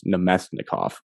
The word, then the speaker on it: Namestnikov: The name Evan meant to say there, I believe, was I'll help you pronunciation wise. It was Namestnikov.